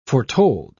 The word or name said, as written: foretold